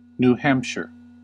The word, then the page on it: New Hampshire: /nuːˈhæmpʃɚ/; American English